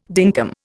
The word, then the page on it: dinkum: Indian